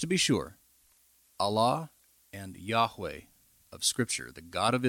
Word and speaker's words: Yahweh: that he is Yahweh.